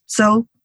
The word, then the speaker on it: Chou: You know, I always tell people you don't need to know that it would be pronounced Jo because it's spelled C-H-O-U. And on the mainland of China, it's Z-H-O-U. And different dialects pronounce it a little bit differently.